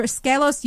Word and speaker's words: Skelos: Skelos, is that how you pronounce him? – Yeah, I think you say it's Skelos. I've seen it pronounced Skelos, Skelos, yeah.